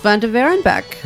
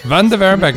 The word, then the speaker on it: Van Waerebeek: We have Ruth Van Waerebeek. – Van Waerebeek, nice pronunciation.